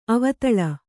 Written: ಅವದಳ

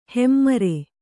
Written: ಹೆಮ್ಮರ

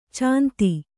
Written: ಛಂದ

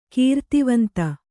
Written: ಕೃತಿವಂತ

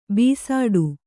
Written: ಭಿಸ್ಸಟೆ

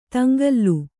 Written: ತಂಗಾಲ

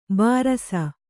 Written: ಭೃಶ

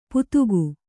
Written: ಪುದುಗು

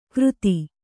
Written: ಕಾರ್ತಿ

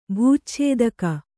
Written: ಭೂಚ್ಛೇದಕ

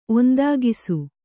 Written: ಒಂದಾಗಿಸು

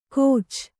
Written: ಕೋಚ್

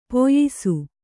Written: ಪಯಸ್ಸು

